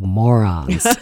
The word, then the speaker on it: Moren: I was in Ireland a few years ago researching at your request the family name, which is spelled M-O-R-E-N. And I was quickly told that we are a family of morons. It's pronounced moron.